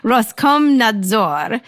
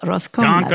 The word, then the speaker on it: Roskomnadzor: This week, Russian regulator, and I'm going to pronounce this, Roskomnadzor, told Google, Roskomnadzor.